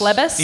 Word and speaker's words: Phlebas: Phlebas.